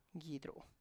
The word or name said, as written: gidru